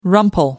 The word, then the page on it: rumple: American English